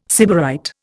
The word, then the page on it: sybarite: Indian